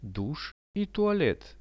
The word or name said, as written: dush i tualet